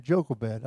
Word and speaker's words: Jochebed: Called her Jochebed all these years, and I just went, the internet is so wonderful to see how it's really supposed to be pronounced. It's actually Jochebed. I've been saying it wrong for 50 years.